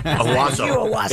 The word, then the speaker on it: Owosso: Owosso. Isn't that how you say it? Somebody complimented me for saying it right.